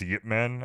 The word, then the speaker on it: Ip Man: It's pronounced Yip Man, I found out recently. Mm-hmm. Like, I was kind of curious. I was like, I feel like we're not saying it right. And it is, like, it's pronounced with a Y, like Yip Man.